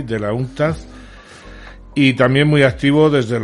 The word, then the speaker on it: UNCTAD: UNCTAD, creo que se pronuncia así. UNCTAD, sí. UNCTAD.